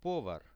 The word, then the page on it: povar: Russian